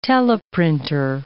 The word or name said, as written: teleprinter